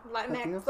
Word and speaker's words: Latinx: Because, okay, if you think about it in Spanish, that it's not how when you say Latinx, you're saying the letter X. You're not pronouncing the letter.